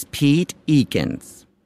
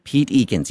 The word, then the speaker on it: Pete Eakins: Oh, my name is Pete Eakins, and I'm one of the administrators. Pete Eakins, yes, Pete Eakins.